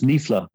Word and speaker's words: Niefler: So I'm probably going to butcher this for the American audience, but I believe the lady's name is Jean Niefler or Niefeller. – That's close. Niefler, yes. – Niefler, okay. So it, more down the Germanic route then of how to pronounce that.